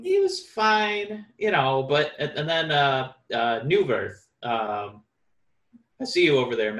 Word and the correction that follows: Neuvirth: It's Neuvirth. You were close. – Neuvirth.